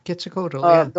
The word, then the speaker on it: Quetzalcoatl: Quetzalcoatl? – Yeah, Quetzalcoatl.